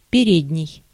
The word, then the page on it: précédent: France (Paris); /pʁe.se.dɑ̃/